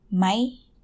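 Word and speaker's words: mai (ไหม): The word is written as mai, rising tone, but when you pronounce it, it's always mai, so it will be a high tone.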